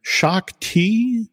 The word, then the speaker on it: Shaak Ti: After having watched the Gennady Tartakovsky, hand-drawn version, there's a character called, see if I'm pronouncing it right, Shaak Ti, that's right.